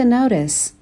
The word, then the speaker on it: notice: Besides just her parents, she began to notice. Notice. Notice. میتونین این تی رو در واقع کمی شبیه به دی تلفظ بکنین.